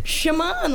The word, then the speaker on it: Shamaun: Shamaun, like what Michael Jackson Shamaun. – Said.